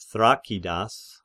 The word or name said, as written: Thrakidas